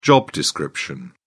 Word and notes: job description: British English

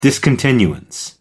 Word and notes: discontinuance: American English

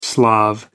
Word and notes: slav: American English

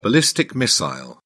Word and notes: ballistic missile: British English

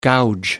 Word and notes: gouge: American English

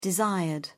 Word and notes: desired: British English

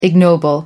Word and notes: ignoble: American English

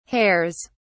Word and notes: hairs: British English

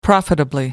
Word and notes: profitably: American English

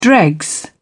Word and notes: dregs: British English

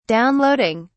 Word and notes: downloading: British English

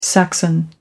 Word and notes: saxon: American English

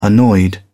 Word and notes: annoyed: British English

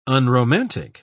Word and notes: unromantic: British English

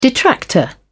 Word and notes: detractor: British English

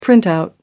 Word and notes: printout: American English

